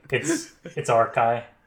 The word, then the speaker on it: Archi: Now, did I pronounce it correctly this time? – No, I should have said something about that before we started, but it's okay. It's Archi.